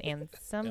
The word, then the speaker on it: Ansem: Is it Ansem? It is Ansem? Yep. Is that how you say it? – Yeah.